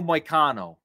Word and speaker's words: Moicano: Renato Moicano. – Moicano. Yeah. I know he fought Dober, and that was, you know, Dober's Moicano. I gotta tell you something about Moicano.